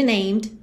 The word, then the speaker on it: named: O E de named não é pronunciado.